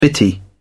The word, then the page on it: bitty: British English